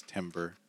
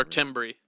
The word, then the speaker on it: timbre: Of them, then they will read it as timber every time. – Mm-hmm. Mm-hmm. – And then you know that they're not. – Or timbre. – Mm-hmm. – Timbre. – Or timb. Silent R-E. – Yeah, the French, yeah. – Or timer, silent B, replaced E-R. A lot of people will do that. But timbre, yeah. Isn't that a weird spelling?